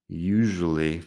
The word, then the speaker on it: usually: Usually. Fíjate en la pronunciación de la S ahí. Usually.